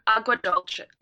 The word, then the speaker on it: Agua Dulce: The next one, Agua Dulce. – Agua Dulce.